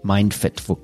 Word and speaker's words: MindFit: MindFit for short, it's pronounced.